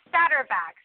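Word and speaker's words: Saterbak: And what about the holding in, how you pronounce it, Satterback? – Yeah, it's Satterback.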